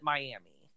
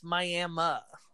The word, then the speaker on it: Miami: And the funny thing is that everyone that's not from Oklahoma pronounces it Miami. – Yeah. – And I'm like, no, it's Miami.